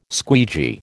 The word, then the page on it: squeegee: American English